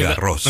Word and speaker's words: Garros: Como ahora que se está disputando el torneo de Roland Garros, usted ya nos había explicado que no es Roland Garros. – No, es Garros. – Roland Garros, Garros, sí. – Claro, es como la calle Jean Jaurès. – Exacto. – Y esa S final se pronuncia.